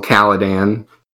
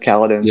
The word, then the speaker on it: Caladan: Who lives in Castle Caladan. That's how I'd say that. – Yeah. – That's how you say it too? – That's how I say it in my head. Caladan.